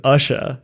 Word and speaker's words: Usha: And then the doctor's name is Usha. – Yeah. Like Usher? – Like Usher spelled phonetically in the way that you would say it.